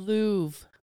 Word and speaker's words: Louvre: Is that how you say it? – The Louvre. – The Louvre. Okay, so I don't pronounce the R. The Louvre.